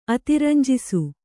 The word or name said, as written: ಅತಿರಂಜಿಸು